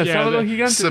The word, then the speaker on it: Sábado Gigante: I wanted somebody that knows how to say it correctly to say it, not me. – Yeah, Sábado Gigante.